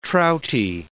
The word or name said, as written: trouty